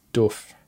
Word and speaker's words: doof: Oh, you got to pronounce that word right. It's doof. Everyone in America says doof. – How do you say it? – Doof. – Duff. Doof. Duff. Oh, okay. – It's like a ooh, like oof.